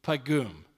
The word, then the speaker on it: paggim: Now it's spelled P-A-G-G-I-M, but it's pronounced in Hebrew pagum.